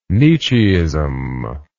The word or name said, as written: Nietzscheism